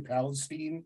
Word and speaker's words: Palestine: Did I pronounce that correctly? Palestine. – Yeah, that's Texas? Right. That's right.